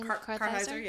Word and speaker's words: Kartheiser: Kartheiser, yeah.